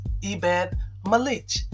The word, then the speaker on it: Ebed-Melech: And his name is Ebed-Melech. And that's spelled E-B-E-D-M-E-L-E-C-H.